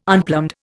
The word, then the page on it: unplumbed: Indian